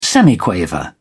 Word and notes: semiquaver: British English

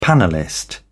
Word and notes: panellist: British English

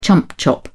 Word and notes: chump chop: British English

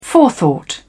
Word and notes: forethought: British English

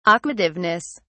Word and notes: aquativeness: British English

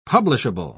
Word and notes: publishable: British English

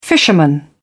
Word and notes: fisherman: British English

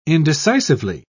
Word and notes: indecisively: British English

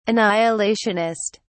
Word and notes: annihilationist: British English

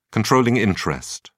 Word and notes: controlling interest: British English

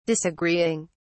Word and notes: disagreeing: British English

